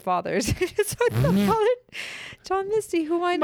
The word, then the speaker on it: Father John Misty: Father John Misty? Is that, did I get the name right? – Father John Misty.